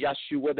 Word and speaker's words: Yeshua: Yes, we can say Yeshua. That is the proper Hebrew pronunciation, but God understands the heart of the matter.